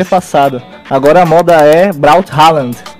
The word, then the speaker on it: Braut Haaland: Meu destaque vai pro Braut Haaland. – Aí a pronúncia saiu, mano. Isso aí.